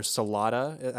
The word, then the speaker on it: Salata: It aired on October 11th of 2007, was directed by Ken Whittingham and written by Jennifer Salata, I think is the pronunciation we agreed on.